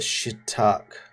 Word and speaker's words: Chittock: or Chittock, C-H-I-T-T-O-K. How would you pronounce that? – Chittock. – Yeah, Chittock.